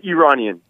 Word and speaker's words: Iranian: Did you say Iranian? Is that how you pronounced it? – Iranian. – Iranian. Okay.